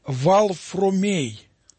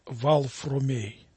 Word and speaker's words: Варфоломей: Оно произносилось не как Варфоломей, а произносилось как Валфромей. Валфромей. Вот так наши предки произносили это имя, так к нему обращался его отец Кирилл, мать его Мария.